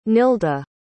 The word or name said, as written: nilda